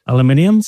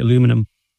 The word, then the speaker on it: aluminium: Do you say aluminium still? Or do you say aluminum? – Oh, aluminium.